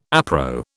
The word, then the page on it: appro: British English